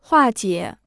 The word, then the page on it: 化解: huà jiě